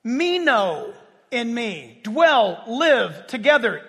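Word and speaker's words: Meno (μένω): It's spelled like it sounds. Meno. M-E-N-O.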